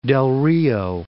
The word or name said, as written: Del Rio